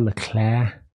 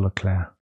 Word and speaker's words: Leclerc: I think I understand the way to pronounce it that I sometimes mess up. Charles Leclerc. You know, like the woman's name, Claire. Charles Leclerc.